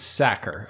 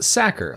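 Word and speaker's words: Sachar: It should be pronounced Sacker, like someone who tackles quarterbacks, or someone who stuffs potatoes into sacks.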